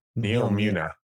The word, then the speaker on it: Neomuna: We got a glimpse of the new destination on Neptune called Numora. I hope I'm pronouncing it right. – What's it called? – But it is called Neomuna. There we go. – Neomuna.